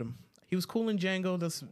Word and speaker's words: Django: And he said the J is silent. – So it's no. Django. – Shut up. I messed up. – Game over, yeah! I messed up. It's the D is silent.